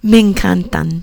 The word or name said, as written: me encantan